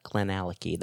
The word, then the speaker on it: Glenallachie: Yep, Glenallachie. That's okay. how you pronounce it. – Okay, Glenallachie.